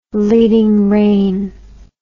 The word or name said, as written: leading rein